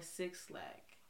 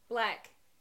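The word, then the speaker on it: 6lack: Giveon, Lucky Day, Brent Faiyaz, or Six Lack. – Black. – Black. – It's just Black. That's how you say it. – Yeah, we all say Black.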